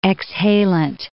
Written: exhalent

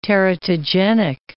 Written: teratogenic

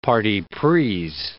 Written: partis pris

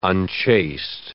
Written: unchaste

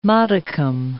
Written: modicum

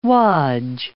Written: wodge

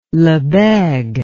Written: lebesgue

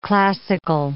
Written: classical

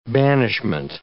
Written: banishment

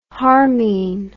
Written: harmine

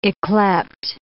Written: yclept

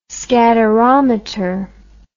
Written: scatterometer

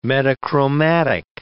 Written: metachromatic

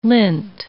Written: lint

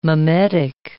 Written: mimetic

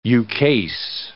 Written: ukase

